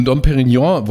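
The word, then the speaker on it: Dom Pérignon: Genau, also wenn du ein Dom Perignon, wollte ich sagen, zum Beispiel nimmst, ja, ja dann, ja.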